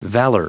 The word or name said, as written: valor